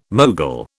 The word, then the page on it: mogul: British English